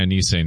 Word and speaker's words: Enes: He goes by Anise now, please. – Excuse me, Anise.